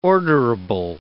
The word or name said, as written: orderable